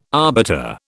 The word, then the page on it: arbiter: British English